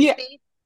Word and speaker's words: Spieth: It's, I may say his name wrong, but the golf player, Spieth. – Jordan Spieth.